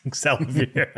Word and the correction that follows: Olivier: I can't say X. – It's Olivier. We'll get it by the end. – Whatever. – It's Olivier. – I'm sorry I butchered his name so hard.